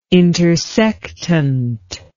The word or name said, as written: intersectant